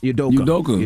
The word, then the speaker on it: Udoka: Udoka. – Udoka.